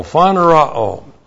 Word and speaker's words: phanerao: P-H-A-N-E-R-O, long O, phanerao.